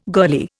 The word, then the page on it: girlie: Indian